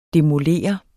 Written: DEMOLER

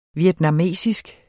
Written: vietnamesisk

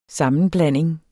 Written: SAMMENBLANDINGEN

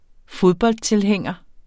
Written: fodboldtilhænger

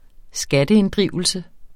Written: skatteinddrivelse

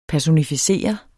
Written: personificere

